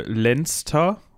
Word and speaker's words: Leinster: Ja, ähm, ich glaube, die Provinz, was du eben äh, Lannister ausgesprochen hast, ist, glaube ich, äh, Lenster oder Leinster.